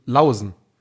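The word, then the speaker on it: Laursen: wie spricht man das richtig aus? – Lawson. Jakob Lawson.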